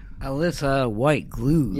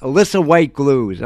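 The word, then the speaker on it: Alissa White-Gluz: And then yesterday was Alyssa Glues White. Is that how you say her name? – Alyssa White Glues. – Yes, yeah. Alyssa White Glues.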